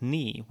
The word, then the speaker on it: knee: This is why we spell words like knee with a K, because we used to say it with a K.